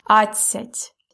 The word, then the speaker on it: адцять: Адцять. – We spell it A, D, C, Я, Т and a soft sign. But we pronounce it really fast. That's why we don't hear D. It's адцять.